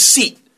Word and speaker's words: seat: 예, seat 하면서 길게 발음하셔야 돼요.